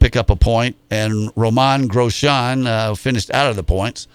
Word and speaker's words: Grosjean: But I Googled it last night, and as a lady, will pronounce it for you several times, and it's Romain Grosjean. So I wasn't even close.